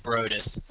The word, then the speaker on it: Brodus: Is it Brodus? Brodus? – Yeah, Brodus.